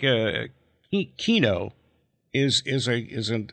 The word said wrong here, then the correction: Keanu: Is it Kino or Keanu? He said people call him Keanu. It's actually pronounced Keanu.